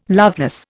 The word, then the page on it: loveless: Indian